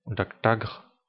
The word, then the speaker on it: Daktag: Daktag, qui se prononce en Klingon Daktagre, c'est un poignard doté de petites lames supplémentaires formant une garde.